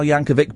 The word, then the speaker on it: Yankovic: It's a hard C, Weird Al Yankovic. He's been on the show a couple of times. It's Weird Al Yankovic, but we love Weird Al.